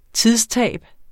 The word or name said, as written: TIDSTAB